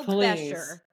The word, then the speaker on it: Bescher: Milo Bescher.